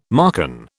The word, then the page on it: Marcan: British English